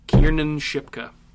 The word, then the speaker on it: Kiernan Shipka: There are lots of other people in the world that have the name Kat that's spelled K-A-T. – That's great. – Can we talk about this girl's name, though, real quick? Kieran Shipka? – Kiernan Shipka.